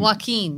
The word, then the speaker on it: Joaquin: Joaquin, that's right.